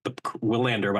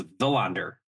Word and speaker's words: Willander: And the first guy we're going to hit on there is Tom, I believe you, Willander, but I think it's Villander is the way you pronounce his name.